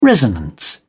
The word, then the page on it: resonance: British English